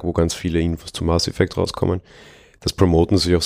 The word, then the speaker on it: Mass Effect: Mass Effects. Wie spricht man das aus? – Mass Effect. – Ohne S. Effekt, ohne S, genau.